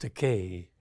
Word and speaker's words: Takei: It's pronounced Takei. However, Takei is a legitimate Japanese word, which means expensive. So they'll have to pay up if they want to insist that I'm expensive. But Takei rhymes with other words like day, say, may, play, gay.